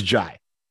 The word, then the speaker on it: Jai: And on the Texas website, it says, pronunciation, J-A-Y, J. So it's J. – So here's the funny thing about that. He played at Texas, as you probably know. And when he played at Texas, he was Jai. That was how they pronounced his name.